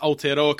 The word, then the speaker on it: Aotearoa: It's very good that someone that I've guested on a podcast has actually pronounced Aotearoa correctly.